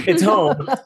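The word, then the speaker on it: Houm: And it's home spelled phonetically. So it's H-O-U-M. So if you got to remind yourself, you can call it a hoem, but it's home.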